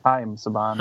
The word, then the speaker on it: Haim Saban: Now, legend has it that Mr. Is it pronounced Haim Saban? – Haim Saban. – Haim Saban, who...